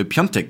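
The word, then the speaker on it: Piątek: Und da steht halt Piatek auf dem Trikot. Und wir wurden wirklich wüst beschimpft dafür, dass wir ihn richtig ausgesprochen haben, weil wir Piontek gesagt haben.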